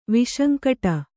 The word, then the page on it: ವಿಸಂಕಟ: visankaṭa